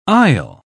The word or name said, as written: aisle